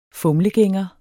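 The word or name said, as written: FUMLEGÆNGER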